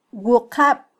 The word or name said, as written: wuqab